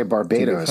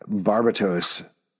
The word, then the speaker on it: Barbatos: Oh, I thought you were going to say Barbados or something like that. Barbatos is actually how you pronounce it.